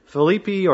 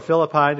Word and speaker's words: Philippi: He's writing to the church in Philippi or Philippi, depending on which reference you look, is how it's pronounced.